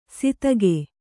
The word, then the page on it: ಸಿತಗೆ: sitage